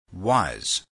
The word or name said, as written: was